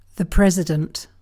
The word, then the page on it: president: British English